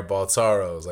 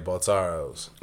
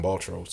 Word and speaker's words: Baltoro: Baltoros. Like he's oh, that's how you pronounce it, Baltoro. – Yeah, Baltoros. – I call them Baltros because I'm stupid.